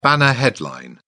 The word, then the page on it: banner headline: British English